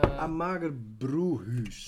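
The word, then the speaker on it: Amager Bryghus: Je burger, Briekhuis. Zeg ik het goed? Ja, jij bent... Uh, Amager Broehuis.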